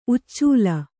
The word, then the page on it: ಉಚ್ಚಲ: uccala